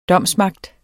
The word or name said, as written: domsmagt